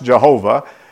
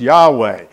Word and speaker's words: Yahweh: But when you put capital L, capital O, capital R, capital D, that's to alert you that in the Hebrew text, that's the word that used to be pronounced Jehovah, and scholars decided it should be pronounced Yahweh.